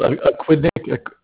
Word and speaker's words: Aquidneck: They're mentioning that the billing address that you have, that is 855 how do you say that? Aquidneck. – It's called Aquidneck. Aquidneck Avenue.